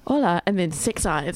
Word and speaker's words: hola: I'm pretty sure the H is silent, so you've been running around Spain going, holla, holla. – It's actually hola.